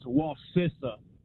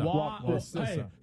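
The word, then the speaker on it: Wacissa: It's Wasissa. I grew up in that area. – Wasissa? Like sister. – Wasissa.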